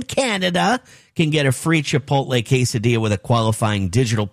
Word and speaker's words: Chipotle: Chipotle. Say it right. Don't say Chipotle. Okay? Chipotle. Chipotle. Say it one time.